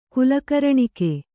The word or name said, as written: ಕುಲಕರ್ಣಿಕೆ